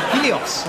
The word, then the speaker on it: Helios: Helios. Am I pronouncing that right? – Yes, that's it.